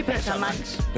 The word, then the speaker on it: Bessermanns: Wir sind die Bessermanns. – Besser? Mann? Betonung auf besser. – Soll das das Paradies sein? Ja, Adam und Eva, ja. – Die Zivilisierten. – Aber die heißen Bessermanns.